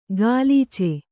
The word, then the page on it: ಗಾಲೀಚೆ: gālīce